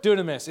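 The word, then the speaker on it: dunamis (δύναμις): Dunamis. It's actually spelled dynamis, but it's pronounced dunamis, and it's where we get the word dynamite.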